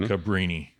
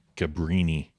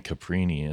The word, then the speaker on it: Cabrini: So William Gates lived in Caprini Green. – Cabrini. – Yeah, what's that? – Brini. Cabrini. – Cabrini? – Brini. B-R. – Have I been saying that wrong my entire life? – Caprini. You're saying Caprini. It's not like Capri.